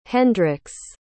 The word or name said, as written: Hendrix